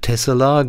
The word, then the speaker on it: Texel: Die heißen im Niederländischen Tessela, genauso wie die Schafe, und die Insel heißt auch nicht Texel, sondern wird Tessel ausgesprochen.